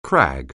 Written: crag

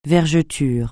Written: vergetures

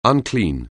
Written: unclean

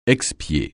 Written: expier

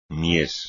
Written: mies

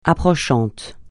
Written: approchant

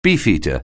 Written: Beefeater

